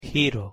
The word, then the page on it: giro: Spanish